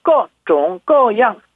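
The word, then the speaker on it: 各种各样: 중국어 한자로는 우리말 한자 발음은 각종각양. 그리고 중국어 발음으로는 꺼종꺼양.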